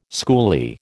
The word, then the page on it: schoolie: American English